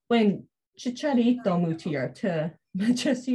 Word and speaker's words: Chicharito: So I guess the story behind their friendship is Checarito is also from... Am I pronouncing that right? – I think Chicharito. It's Chicharito.